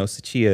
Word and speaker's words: Tsuchiya: I know Tsuchiya is how you would say his last name.